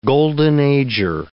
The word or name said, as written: golden-ager